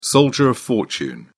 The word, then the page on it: soldier of fortune: British English